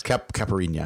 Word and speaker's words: Caipirinha: This comes from Deep Caipirinha. Do I say that right? It's the Brazilian alcohol drink. – Yes, Caipirinha, I think.